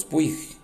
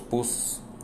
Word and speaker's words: Puig: Todo el mundo le decía Carlos Puig. Y un día nos dijo, no, es que se pronuncia Puz, Carlos Puz, pero se escribe Puig, P-U-I-G.